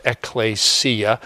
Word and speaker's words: ecclesia: In Greek, it's pronounced ecclesia. Okay? It comes from two parts. Ek is like in exit. You know, we spell exit E-X-I-T, but it's really E-K-S-I-T.